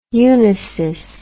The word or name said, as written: unisys